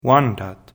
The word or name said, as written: Wendat